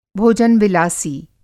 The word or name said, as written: भोजन-विलासी